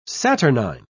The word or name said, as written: saturnine